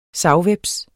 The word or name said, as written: savhveps